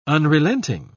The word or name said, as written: unrelenting